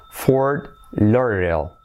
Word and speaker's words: Fort Lauderdale: Porque es Lauder, esa D es casi como una R, Lauder, de como R suave, ¿vale? Semiclave, Lauderdale. Y luego la L después, ¿vale? Fort Lauderdale.